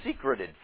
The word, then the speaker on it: secreted: David finds himself in a net that they secreted for me. That is the way you pronounce it. I know secrete sounds like something that a gland does.